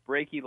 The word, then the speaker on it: Brackey: It's Brackey like achy, breaky heart.